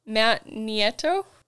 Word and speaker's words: Nieto: And then you're going to correct me on this last name because I'm going to say it wrong. Matt Nieto? – Pretty close. I'll let it be. That let it lie. That was so close that I'm not going to correct it.